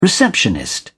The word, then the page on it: receptionist: British English